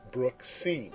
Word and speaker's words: Brooksine: However, they had a girl, for which I am so grateful, and named her Brooksine. She often assists new acquaintances with the unusual pronunciation by saying, like the scene of a brook, just turn it around, Brooksine.